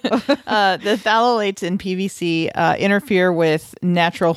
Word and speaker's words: phthalates: It's the phthalates in PVC, and it has it starts with a PH. It's phthalates, and yeah. – And she goes, well, the pH is silent.